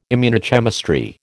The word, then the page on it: immunochemistry: American English